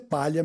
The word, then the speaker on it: Paglia: Eu tenho no Instagram o arroba Ernesto Paglia, né, que a pronúncia é palha mesmo. Porque quer dizer exatamente isso, palha, mas é em italiano, então é escrito Gli.